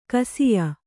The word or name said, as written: ಗೋಸಾಯಿ